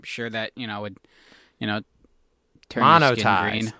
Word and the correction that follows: monetize: It's monetized. – Yes. – Montanize. Monetize. Monetize is the word we're looking for.